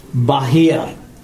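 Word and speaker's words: bahia (בחיר): The word elect one there is the Hebrew word bahia. Bahia. It is spelled B-A-C-H-I-Y-R. B-A-C-H-I-Y-R.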